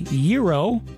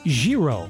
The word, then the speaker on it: gyro: How do you say the word spelled G-Y-R-O? Some people say gyro, others say gyro.